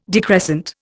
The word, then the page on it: decrescent: Indian